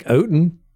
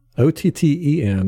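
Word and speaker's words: Otton: It is actually spelled Cade Otten. Otten. That's how you pronounce it.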